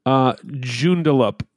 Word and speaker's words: Joondalup: Joondalup. – I think that's actually correct. Joondalup. Yep. Now, Joondalup. – It's spelled J-O-O-N-D-A-L-U-P.